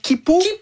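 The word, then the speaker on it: Quipu: Et si vous pensiez que c'était déjà vertigineux d'imaginer l'Anéakea, attendez qu'on vous parle de comment Kipu. Ça s'écrit Kuipu. – Oui. Donc Kipu, c'est deux fois plus grand que l'Anéakea.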